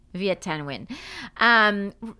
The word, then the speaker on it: Viet Thanh Nguyen: Viet Thanh Nguyen.